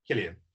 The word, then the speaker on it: Cillian: And obviously that's against, is it Cillian or Killian? – Killian.